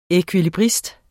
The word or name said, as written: ekvilibrist